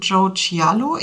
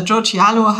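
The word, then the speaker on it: Joe Chialo: zu Joe Cialo. Ich weiß nicht, ist das, mhm. spricht man den wirklich richtig so aus? – Ich würde es auch so sagen.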